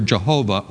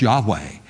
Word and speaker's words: Yahweh: the word Jehovah, or probably better pronounced Yahweh.